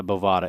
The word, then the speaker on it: Bovada: It's Nevada Bovada. It's BOVADA.LV. I always forget how to pronounce it, but it sounds like Nevada, but it's Bovada.